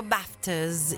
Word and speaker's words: BAFTAs: E noi avevo promesso che avremmo parlato di BAFTAs, che si pronunciava BAFTAs in British English, che sono gli Oscar britannici, ovvero British Academy Film and Television Awards.